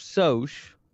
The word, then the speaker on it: Sosh: It's Sosh.